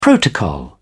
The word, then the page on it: protocol: British English